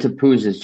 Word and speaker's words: Topouzis: And Topouzis, just so people know, TOPOUZIS. – That's right. – I would have never got it. You pronounced it perfectly, better than me.